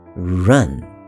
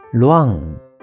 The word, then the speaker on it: run: 第一个，跑步很简单，叫做 run。 OK，有些台湾人会把它念成 run。 Run 哦，注意哦，跑步它的母音跟尾音是念 n，n，前面加一个卷舌头的 r，所以加起来就是 run。